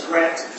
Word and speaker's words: Grant: My name is Andre Grant. We have the requesting 5 minutes for rebuttal. – Thank you. Counsel, would you spell your last name for me, please? – Last name is Grant, G-R-A-N-T.